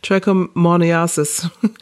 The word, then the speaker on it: trichomoniasis: I've tried practicing that word. trichomoniasis. you got it.